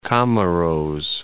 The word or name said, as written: Comoros